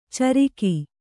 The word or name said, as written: ಚರಿಗೆ